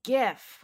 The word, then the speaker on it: GIF: Newsweek declares it's GIF with a hard G.